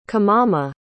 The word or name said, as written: kimama